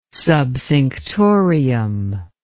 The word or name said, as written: subcinctorium